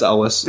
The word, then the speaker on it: Elwes: Ewells. – Carrie Elwes? – Sorry, Elwes. I don't know how to say his name. – Yeah, it's Elwes. – There's not a U in that entire name.